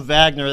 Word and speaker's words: Wagner: His name is pronounced Wagner Decisse, and here in the United States might say Wagner, but I'm going to call him Wagner. That's the way they say it down there.